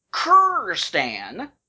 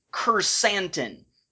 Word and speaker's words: Kursantan: And then there's this big, burly, but we don't know very much about him at all, Wookiee named Kurrstan, or Kurrstan, or I guess it's not Kurrstan, I'm missing a syllable, it's Kursantan, Kursantan.